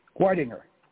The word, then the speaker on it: Weidinger: Phil, am I getting the pronunciation of the last name correct? – Wettinger. A nice, a good Wettinger. German name.